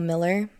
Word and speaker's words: Millar: It's Christina Nicole Miller. No spaces, no dots, no nothing. My last name is spelled M-I-L-L-A-R.